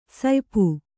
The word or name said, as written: ಸುಯ್ಪು